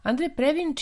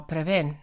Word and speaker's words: Previn: Andre Previn, čije prezime u Evropi pa i kod nas često izgovarano kao Preven, rođen je zapravo kao Andreas Ludwig Privin u porodici nemačkih Jevreja.